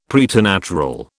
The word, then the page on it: preternatural: British English